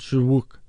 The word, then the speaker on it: Shyriiwook: The native language is called Shurwook. For those of you who are taking notes at home, that's spelled Shyriiwook. So write that down. Shurwook, I believe is what it's called.